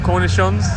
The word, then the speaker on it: cornichons: With new potatoes, cornicons, and melted cheese. – Corny what? – Cornicons, is that how you say it? – Cornichons? – Is it cornichons? – Either or, potato, potato.